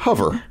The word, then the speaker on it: hover: You hoover. Or you, how do you say it? I don't say it right. – Hover. – Hover. – Close. – I always say hoover, and they always make fun of me for it.